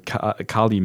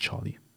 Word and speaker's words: Carli: Ich weiß gar nicht, spricht man sie Kali aus? Ich denke mal C-A-R-L-I. – Sieht danach aus, ja. – Nicht Charlie.